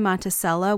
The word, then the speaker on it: Monticello: Nearby Monticello, which is how they say it, is listed as surely a sundown town.